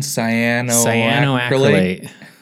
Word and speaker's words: cyanoacrylate: Cyanoacrylate.